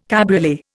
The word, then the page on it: cabriolet: Indian